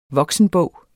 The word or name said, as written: voksenbog